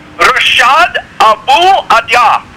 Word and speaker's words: Rashad Abu Adya: Rashad Abu Adya.